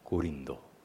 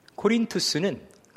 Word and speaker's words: 코린투스: 그 아카이아 지역의 수도가 고린도, 원래 발음으로 코린투스입니다. 코린투스는 그리스 지역에서 당대 이제 시간이 지나서 로마가 지중해의 패권자가 됐던 시기에 그 지중해 세계에 유의학의 대학이 있던 곳입니다.